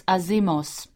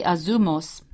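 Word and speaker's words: azumos (ἄζυμος): It's azimos. It's really azumos. It's pronounced azumos.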